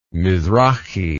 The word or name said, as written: mizrachi